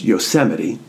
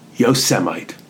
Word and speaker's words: Yosemite: Rather than reading the park's name as Yosemite, and frankly to me, it doesn't look like it should be pronounced that way, he pronounced it as Yosemite.